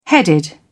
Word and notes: headed: British English

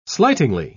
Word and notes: slightingly: British English

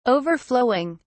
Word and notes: overflowing: British English

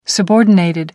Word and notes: subordinated: American English

